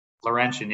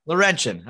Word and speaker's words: Laurentian: but uh Laurentian University. – Laurentian, yeah. – Laurentian.